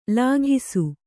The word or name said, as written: ಲಾಘಿಸು